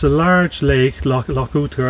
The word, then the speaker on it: Oughter: It's Loch Ooter, O-U-G-H-T-E-R, but it's pronounced Ooter.